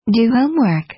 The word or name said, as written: do homework